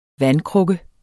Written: vandkrukke

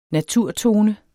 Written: naturtone

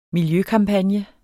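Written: miljøkampagne

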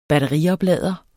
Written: batterioplader

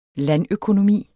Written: landøkonomi

